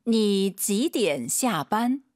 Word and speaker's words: 你几点下班: 你几点下班? – 삼성이 3개 이상이면 그때그때 성조가 달라집니다. 근데 주어부터 시작하는 삼성이 연속 3개 있으면은요 앞에 이 니는 반삼성입니다. 올라가는 순간 틀린 발음이에요. 니 하면 안 됩니다.